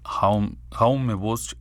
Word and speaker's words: Jaume Bosch: O en su en su pronunciación catalana, que es Jaume Bosch i Rehart.